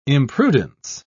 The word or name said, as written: imprudence